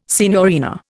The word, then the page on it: signorina: Indian